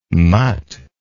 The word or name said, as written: mut